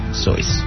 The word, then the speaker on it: Seuss: It was at Dartmouth that he started using the pen name Seuss, pronounced like his mother's maiden name.